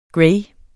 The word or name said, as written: gray